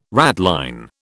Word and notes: ratline: British English

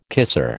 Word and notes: kisser: American English

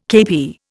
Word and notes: KP: Indian

